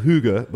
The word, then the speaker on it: hygge: I believe it's called hygge, but it looks like it's spelled hygge. But it's something when I went to Copenhagen, like a lot of places there have.